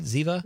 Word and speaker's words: Ziva: Am I saying that right? Ziva? – You are saying that right.